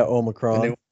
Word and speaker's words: Omicron: Yeah, Omicron.